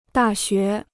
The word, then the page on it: 大学: dà xué